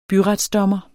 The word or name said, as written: byretsdommer